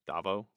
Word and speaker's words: Davo: So after this, we go to Mon Mothma, and she's having her meeting with Davo Skuldon. Is that how you pronounce it? Davo? – Yeah, it's like what they couldn't have called him, like Kevin Scumbag, you know? – Okay.